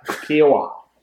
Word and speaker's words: Kiawah: Kiawah.